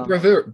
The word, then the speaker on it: bravura: but there is a bravura, and is that how you pronounce the word, bravura? Anyway. – Do you mean bravura? – That's the one, yeah.